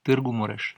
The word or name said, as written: Târgu Mureș